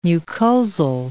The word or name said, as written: mucosal